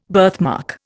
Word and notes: birthmark: Indian

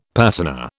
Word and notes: patina: British English

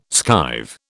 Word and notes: skive: British English

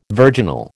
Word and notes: virginal: American English

